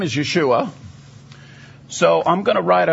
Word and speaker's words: Yeshua: And in Greek, that transliteration is spelled Yeshua. That's how you would say that.